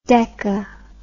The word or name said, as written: deka